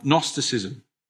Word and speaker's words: Gnosticism: And one of the first challenges that hits the New Testament church is a challenge called Gnosticism with a silent G.